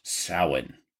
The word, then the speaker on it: Samhain: It was a pagan festival called Samhain, pronounced Samhain.